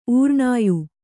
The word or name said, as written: ಊರ್ಣಾಯು